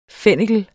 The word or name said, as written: fennikel